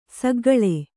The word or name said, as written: ಸೆಕ್ಕೆಗಳೆ